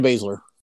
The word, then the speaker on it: Baszler: Oh, Shayna Baszler.